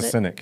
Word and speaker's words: succinic: What it you you you have the right pronunciation of that acid. – Uh, what is succinic. it? – Succinic acid, mm-hmm.